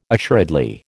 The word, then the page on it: assuredly: American English